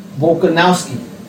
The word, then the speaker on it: Volkanovski: Do you like to pronounce his name? – Volkanovski. – There you go.